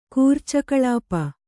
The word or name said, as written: ಕೂರ್ಚಕಳಾಪ